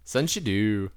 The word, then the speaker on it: Sunshadeau: Alright, so we're gonna butcher this name too. Sasha from Sunshadu Arts. Has yes. – a Kickstarter out. Yes. – Well done. Sunshadu.